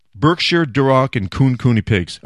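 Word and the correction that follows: Kunekune: Are they Cooney Cooney? Yeah, Cooney Cooney pigs.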